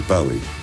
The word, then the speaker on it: Bowie: I always thought it was Bowie. I thought, well, this is a Scottish name, must be Bowie. But nobody in Scotland pronounces it like that. Pronounce it Bowie.